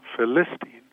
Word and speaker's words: Philistine: And even words, we found words like, you know, Philistine, that in fact the Bible expert wanted the accent on the second syllable, Philistine.